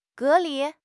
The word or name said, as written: 隔离